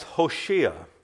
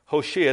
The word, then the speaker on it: Hoshea: Joshua, his name in the Hebrew, literally is pronounced Hoshea. Hoshea, that was his original name, and that meant deliverer or one who saves.